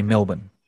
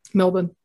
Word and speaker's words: Melbourne: Whenever you speak to an American, and they say, like in Australia, we say Melbourne, you know, we don't pronounce our R. We say Perth, you know, we don't pronounce the R, but Americans pronounce it.